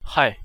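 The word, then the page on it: hai: Chinese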